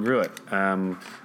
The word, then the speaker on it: Gruet: Yes, the original Gruet. They've actually got one called the original Gruet and one called the OG, which I think is like... a play on words, the OG original Gruet Gangs.